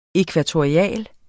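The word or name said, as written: ækvatorialt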